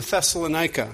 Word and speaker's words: Thessalonica: When they passed through Amphipolis and Apollonia, they came to Thessalonica.